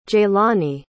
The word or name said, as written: jaylani